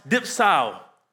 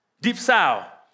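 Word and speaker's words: dipsao (διψάω): It means to strongly desire, while thirst is actually pronounced dipsao, dipsao.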